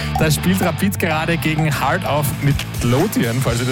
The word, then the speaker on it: Heart of Midlothian: Rapid, völlig richtig ausgesprochen. Heart of Midlothian.